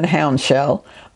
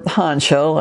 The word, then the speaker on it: Houndshell: One of my paternal great uncles, Benjamin Franklin Houndshell, or Hanshell, as some of the family prefer to pronounce it, was the family historian and genealogist of his generation.